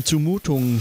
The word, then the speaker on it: Zumutung: Ähm, und äh, in der Schweiz würde man, äh, äh, wie in Deutschland auch, äh, es betonen als Zumutung, ja. Man betont ja alles auf der ersten Silbe.